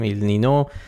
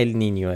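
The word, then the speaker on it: El Niño: تلفظ درست اگه میخواین ال نینیوه، ولی همه جا تو فارسی بچرخید کسی چیکی نمیفته، ال نینیو همه جا نوشتن ال نینو.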